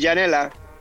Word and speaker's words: Janela: ¿Cómo la pronuncio? ¿Janela o Janela Bat? – Sí, Janela.